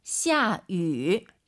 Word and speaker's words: 下雨: 下위, 위 아니죠? – 下雨. – 입술을 동그랗게 말아서 위 하려고 하세요. 下雨. 샤우. 네, 이렇게 발음하셔야 됩니다. 절대 위, 입이 가로로 찢어지면 안 됩니다, 여러분.